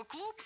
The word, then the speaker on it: клуб: А слово «клуб» звучит в конце «пэшно», хотя пишется «бэшно».